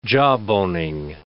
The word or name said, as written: jawboning